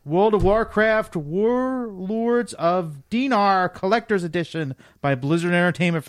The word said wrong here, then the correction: Draenor: I think it's pronounced Draenor.